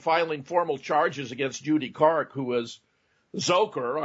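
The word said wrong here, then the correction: Dzhokhar: I'm told it's actually pronounced Joker, the younger brother, for, you know, throwing away his defense in her opening statement saying he did it.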